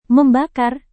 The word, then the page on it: membakar: Indonesian